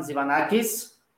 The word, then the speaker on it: Tzivanakis: So who I am, my name is Evan Tzivanakis.